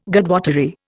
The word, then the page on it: Godwottery: Indian